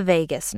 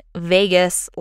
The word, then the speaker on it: vagus: and that is the vagus nerve. I looked up pronunciation for this thing, and the American pronunciation was whack. So I am saying vagus like the British do. So come at me, bro.